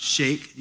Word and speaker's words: Shaikh: The next slide up here, this is so that S-H-A-I-K-H, that is pronounced Sheikh. You can say the Sheikh peoples or Sheikh. If you go on to a website by the organization called Joshua Project, is it .net or .org?